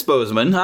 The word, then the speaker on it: Boseman: Yeah, it's Boseman. I thought so.